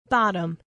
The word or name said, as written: bottom